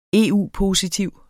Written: EU-positiv

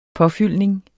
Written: påfyldning

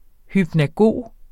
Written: hypnagog

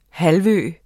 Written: halvø

